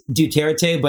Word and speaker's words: Duterte: and it's pronounced Duterte, but I don't respect the man.